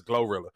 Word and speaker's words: Glorilla: Yeah, Glorilla. – Okay, I didn't know that. I never knew it. Because the way it's spelled, it kind of almost looked like Gorilla, but it's Glorilla.